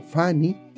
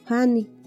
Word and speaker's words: fani: Entonces continuamos con el siguiente que es caballo y al ñañú se traduce como Fani, Fani, Fani y se escribe con la consonante F, A, N, I. Fanny, F-A-N-I. – Para la siguiente palabra que es caballo, su traducción en yoho es Fanny, Fanny, y se escribe con la F-A-N-I. F-A-N-I, Fanny.